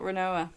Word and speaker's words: Rinoa: Is it like Ronona Renona from Final Fantasy? No, it's not Rinoa.